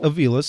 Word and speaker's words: Avila: I've been told it's Avila, but now that when I was in the room, Al Avila said his name was Avila, so that's how it will work out.